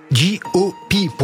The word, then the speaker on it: JOP: J-O-P, pour le prononcer correctement, euh, James the Prophet en, en, combinaison avec Kalash Criminel, excellent morceau recommandé bien évidemment.